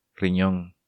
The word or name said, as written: Rinon